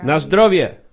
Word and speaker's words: na zdrowie: Na Zdrowie.